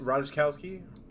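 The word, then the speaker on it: Ratajkowski: I'm going to butcher her name. Rakitaski. – I think it's Radishkowski.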